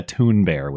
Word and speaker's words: Thunberg: Think sort of like Greta Thunberg, which is the proper pronunciation.